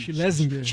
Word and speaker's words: Schlesinger: Do John Schelling. – Schlesinger. – Schlesinger. Schlesinger. – John Schlesinger, aprendendo a pronúncia agora.